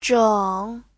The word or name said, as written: zheng3